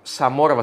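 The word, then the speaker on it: Zamora: Ο Ζαμόρα, Σαμόρα βασικά να τον προφέρουμε σωστά, αποκτήθηκε στην λογική του Πάλμα.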